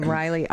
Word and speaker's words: Reiley: And that's the way I always say my name, Riley, R-E-I-L-E-Y.